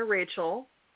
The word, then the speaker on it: Rajchel: So for those confused, I will answer to Rajchel, but I usually go by Diana Rajchel.